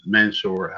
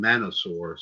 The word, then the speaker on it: Mansoor: He's also going to take Mansoor, how you pronounce his name. I want to call him Manosaur, so I don't know why.